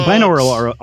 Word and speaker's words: binaural: Binaural? Am I saying it right? – Yeah, binaural.